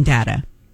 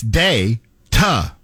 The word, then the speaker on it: data: It's D-A, that's day, ta, not da-ta.